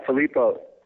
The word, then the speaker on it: Filippo: I know you got the Filippo, is that how you pronounce it, out in Italy? – Yeah, Filippo. Yeah, Filippo and I met in Los Angeles.